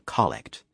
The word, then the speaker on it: collect: This was also my introduction to the kind of prayer called a collect, emphasis on the first syllable.